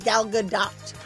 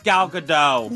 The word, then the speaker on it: Gadot: She says her name is Gal Gadot. – That's what I thought. – It's Gal Gadot. – Nope, that's what you say. That is not how she pronounces her name.